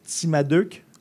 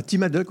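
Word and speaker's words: Timadeuc: je, veux juste bien le prononcer. Timaduc. Ah, Timaduc, oui.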